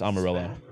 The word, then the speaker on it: Amarillo: Had a pretty stellar outing in his rehab start in Double A Amarillo, and then did a bullpen session yesterday. Reportedly, did you say Amarillo? – Is it? Oh, it's Amarillo.